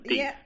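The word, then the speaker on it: D'Eathe: Deeth. Yeah, that's great. – You probably got it correct anyway. It's D. apostrophe, a capital E, small a, T-H-E, Deeth. – Wow. – It rhymes with teeth.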